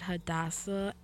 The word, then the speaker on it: Hadassah: You can find me on Facebook at Hadassah N. Thompson. Mm-hmm. That's my Facebook page. My name is spelled H-A-D-A-S-S-A-H.